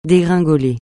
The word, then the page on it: dégringoler: français